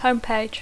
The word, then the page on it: homepage: English